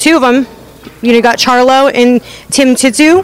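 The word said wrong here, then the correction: Tszyu: Is that how you say it? – Zhu. – Tim Zhu. – Okay, yeah. Sorry.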